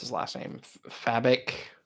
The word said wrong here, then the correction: Fabok: Oh, uh, F- Fabok. Fabok, yeah, yeah, just kind of like very good muscular character.